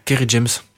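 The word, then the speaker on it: Kery James: Alors, c'est Kerry James, je prononce bien. – Oui, Kerry James.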